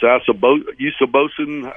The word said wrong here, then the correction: Igbenosen: however you pronounce it. – Igbenosen. – Igbenosen, there we go.